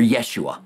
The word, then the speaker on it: Yeshua: Yahshua, or Yeshua, that's how you pronounce it in Hebrew, Yeshua, which has Yahweh salvation in it, in his name.